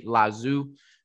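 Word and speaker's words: Lao Tzu: I'll say Lazu. It's L-A-O, first name, last name, T-Z-U.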